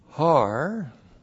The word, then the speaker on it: Har: Har, H-A-R. Har is the Hebrew word for mountain.